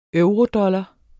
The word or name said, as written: eurodollar